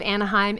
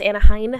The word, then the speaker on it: Anaheim: In the city of Anaheim, and it was officially open to the public. – It's pronounced Anaheim.